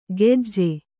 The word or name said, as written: ಗೆಜ್ಜೆ